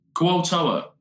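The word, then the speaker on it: Kuo-toa: Kualtoa is how you say it.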